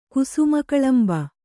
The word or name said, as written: ಕುಸುಮಕಳಂಬ